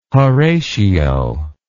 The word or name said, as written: Horatio